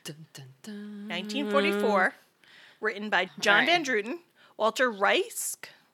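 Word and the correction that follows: Reisch: It's Walter Riesch. – Charles Boyer. – It is Walter Riesch.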